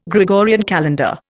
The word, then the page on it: Gregorian calendar: Indian